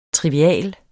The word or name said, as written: TRIVIAL